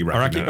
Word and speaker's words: Araki: Aoki or Greg Araki? – Araki.